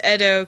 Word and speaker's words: Edo: I thought it was Edo because it's Japanese.